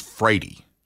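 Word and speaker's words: Wrede: And, you know, when I was, my last name is Frady.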